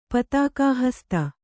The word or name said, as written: ಪತಾಕಾಹಸ್ತ